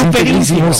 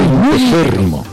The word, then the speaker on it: integérrimo: No se dice integrísimo, se dice integérrimo.